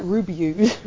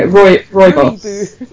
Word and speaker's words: rooibos: Oh, because I always want to call it like Ruby booze, yeah, no, rooibos. – Can I have some rooibos? – Rooibos, vanilla rooibos, yeah, it's very nice.